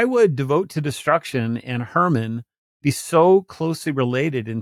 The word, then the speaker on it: Hermon: Now, you wouldn't get that because Hermon, we pronounce it with just the H, the K is silent, mm-hmm. but Hermon is actually spelled K-H-R-M-N.